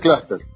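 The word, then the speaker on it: cluster: Mirá, en realidad es clúster, ¿no?, la pronunciación que hemos por mayoría adoptado.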